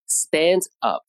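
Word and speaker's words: stand-up: 需要注意的是，这个时候呢，重音就发生变化，变成了第一个词，所以读成 stand up。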